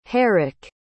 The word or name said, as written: Herrick